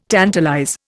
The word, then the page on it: tantalize: Indian